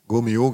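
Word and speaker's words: Gault&Millau: Inzwischen hat er, glaube ich, 4 Go-Mio-Hauben. Ich glaube, so spricht man das aus. Also Go-Milau geschrieben, Hauben. Also, Go-Mio. – Go-Mio, genau. Okay.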